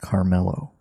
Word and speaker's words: Kamala: I found out you pronounce it Carmelo.